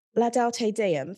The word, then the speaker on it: Laudate Deum: In terms of Laudate Deum, first of all, am I pronouncing it correctly? – You are.